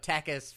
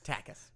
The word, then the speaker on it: Tacas: But it's tactical assault, but tackus for short, and it's spelled T-A-C-A-S. Tackus.